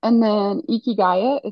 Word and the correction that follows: Ikigai: is, I don't know if I'm saying that right. It's like the Japanese secret to a long and happy life. – Oh, okay. – It's Can you spell that for us? – yes, Ikigai. – Ikigai.